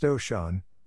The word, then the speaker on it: Osun: Osun, pronounced Oshun, is the Yoruba divinity of the imagination.